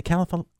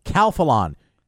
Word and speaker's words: Calphalon: And then Jason goes, how do you say Calphalon. – Calphalon.